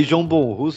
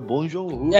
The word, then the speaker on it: Bong Joon-ho: Eu falei João Bonru, você falou João Bonru, é Bong Joon-ho.